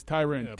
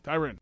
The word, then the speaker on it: Tyron: No, it's Tyron. If you look at the pronunciation guide, according to the NFL, is Tyrin. – Pronunciation guru Tony Baselli. – -RIN. I'm just telling you what it says. Tyrin. Go look it up.